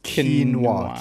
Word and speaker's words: quinoa: So is it quinoa or is it quinoa? – Wait, I think it's quinoa. Quinoa, right? Quinoa. – Quinoa, that's right. I think that's what it is.